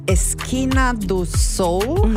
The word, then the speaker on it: Esquina do Sol: Das heißt Esquina do Sol, habe ich es richtig ausgesprochen? – Ja, perfekt.